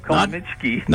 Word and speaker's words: Comaneci: Nadia Comaneci. – Yeah, Comaneci.